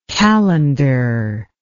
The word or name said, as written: kalendar